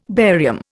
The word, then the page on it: barium: Indian